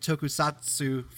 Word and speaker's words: tokusatsu: He's responsible for some great indie slasher and horror films, along with the, you know, a Power Ranger inspired tokusatsu film.